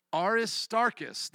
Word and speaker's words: Aristarchus: Articus, Art, or oh, excuse me, R. Aristarchus, there we go, Aristarchus and Secundus.